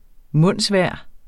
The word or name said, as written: mundsvejr